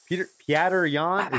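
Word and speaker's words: Petr Yan: Peter Yan.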